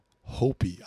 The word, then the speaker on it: Hopi: It is she is Navajo and Hopi. I hope. – Yeah, I yeah, hope, you said it right.